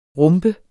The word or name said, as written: rumpe